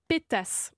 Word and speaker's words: pétas: Qu'est-ce qu'un pétasse Alors, se prononce avec ou sans le S, selon l'endroit où le mot est employé. Ça s'écrit P-E T-A-S.